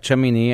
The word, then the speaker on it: Shemini: Shemini, right? Shemini. Mm-hmm. How do you say it? – That's correct. Shemini,